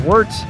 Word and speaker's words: Wort: Go over to YouTube, type in The Wort, spelled Wort, pronounced Wort, and type in The Wort, and you'll bring up our YouTube channel.